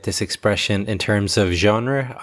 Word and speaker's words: genre: And the pronunciation is a little bit tricky. It's one of these words that comes from French and is kind of pronounced in a French way, genre.